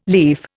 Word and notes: lief: Indian

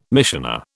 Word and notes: missioner: British English

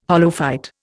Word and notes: holophyte: Indian

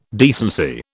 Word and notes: decency: British English

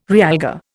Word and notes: realgar: Indian

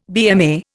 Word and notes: BMA: Indian

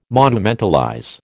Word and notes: monumentalize: American English

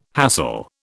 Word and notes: hassle: British English

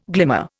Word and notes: glimmer: Indian

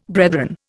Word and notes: brethren: Indian

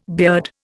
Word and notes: beard: Indian

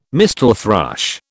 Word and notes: mistle thrush: British English

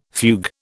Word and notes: fugue: British English

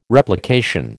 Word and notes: Replication: American English

